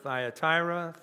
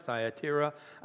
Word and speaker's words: Thyatira: Now, as Bert so aptly pointed out, you can pronounce it Thyatira, Thyatira.